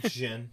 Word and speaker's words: Djin: Why did Jin, like, it's spelled D-J-I-N. – Yeah, Jin, yeah.